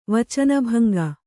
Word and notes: ವಚನಭಂಗ: Kannada

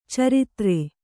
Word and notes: ಚರಿತ್ರೆ: caritre

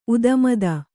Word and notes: ಉದುಮದ: udumada

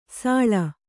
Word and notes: ಸಳಿ: saḷi

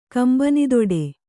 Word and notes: ಕಂಬನಿದೊಡೆ: Kannada

